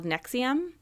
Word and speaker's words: NXIVM: And it is a true crime podcast about a cult called Nexium.